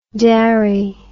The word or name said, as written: darry